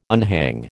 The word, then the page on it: unhang: American English